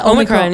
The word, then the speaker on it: Omicron: am I saying it right? – Yeah. – Omicron yeah.